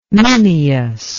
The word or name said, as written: Minyas